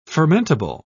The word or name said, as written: fermentable